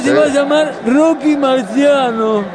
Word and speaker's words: Marciano: Rocky Marciano. No está mal, pero debemos pronunciarlo al italiano. ¡Marciano!